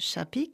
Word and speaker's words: Sapi: Son nom s'écrit Sapi, mais se prononce Chapi car elle est d'origine hongroise.